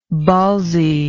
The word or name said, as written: ballsiness